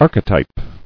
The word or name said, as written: Archetypical